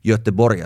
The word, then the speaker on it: Göteborg: PSG Women played Champions League quarterfinal last night, as I'm recording this on Thursday, and they were on the road in Gothenburg, or Göteborg as one would pronounce it, playing against BK Häcken.